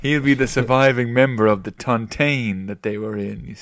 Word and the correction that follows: tontine: I believe it's pronounced Tontine. – Oh, I'm sorry, Tontine.